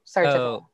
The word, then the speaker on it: Sarajevo: Sarajevo.